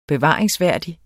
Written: bevaringsværdig

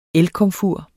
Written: elkomfur

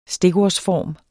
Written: stikordsform

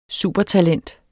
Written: supertalent

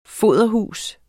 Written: foderhus

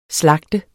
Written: slagte

